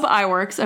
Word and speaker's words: Iwerks: So Oob Eworks, I don't know how to say this, Iwerks, okay.